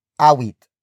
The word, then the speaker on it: awit: Umawit is spelled as U-M-A-W-I-T. It comes from the root word awit.